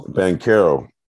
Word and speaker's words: Banchero: Banchero, I'm always pronouncing his last name wrong. – Banchero.